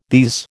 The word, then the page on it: these: American English